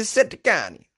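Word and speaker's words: Settecani: The Seticani! You gotta say it like that.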